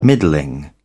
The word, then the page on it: middling: British English